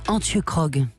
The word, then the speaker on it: Antje Krog: Antje Krog, une poétesse sud-africaine.